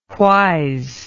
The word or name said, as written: whys